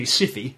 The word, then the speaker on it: Syfy: How is it pronounced, El Presidente? – Definitely Siffy. – Siffy. – Siffy. – S-Y-F-Y.